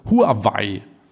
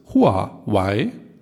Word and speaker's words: Huawei: Von ihrer deutschen Werbung hört man ständig Huawei. – Genau, also was ich gesehen habe oder was ich gehört habe, ist, dass sie beide Aussprachen akzeptieren, aber die chinesisch richtige Aussprache ist Huawei.